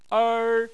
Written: er1

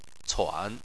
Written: cuan3

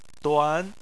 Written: duan2

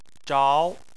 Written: zhao2

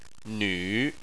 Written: nv3

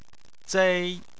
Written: zei1

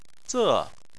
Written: ze4

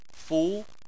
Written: fu2

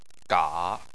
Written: ga3

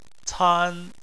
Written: can1

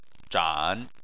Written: zhan3

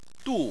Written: du4

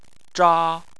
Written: zha1

